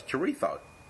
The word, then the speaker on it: chorizo: And he said the correct pronunciation is, because it's Spanish, the Z's actually a... So it's chorizo.